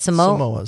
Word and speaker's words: Samoas: Samoas.